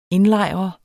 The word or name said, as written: indlejre